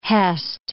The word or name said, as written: hest